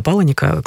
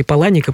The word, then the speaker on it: Паланик: ты метался между двух книг Чака Паланика. Кстати, можно говорить и Паланик, и Паланик, потому что автор еще, по-моему, сам не определился, как правильно говорить его фамилию.